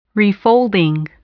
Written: refolding

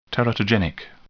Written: teratogenic

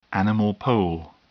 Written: animal pole

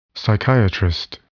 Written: psychiatrist

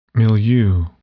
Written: milieu

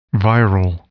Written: viral

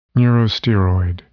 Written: neurosteroid

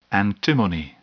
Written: antimony

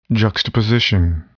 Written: juxtaposition

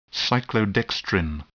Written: cyclodextrin

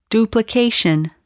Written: duplication

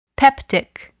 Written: peptic

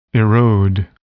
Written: erode

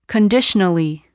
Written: conditionally

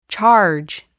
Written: charge

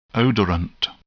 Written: odorant

